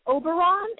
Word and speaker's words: Oberon: and so you were working with, is it Oberon? Am I saying that properly? – Yes.